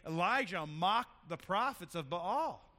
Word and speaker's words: Baal: But it's Elijah and the prophets of Baal, right? You can call them Baal, Baal, Baal. I pronounce most of the vowels. I think that's the way it's supposed to be said, Baal.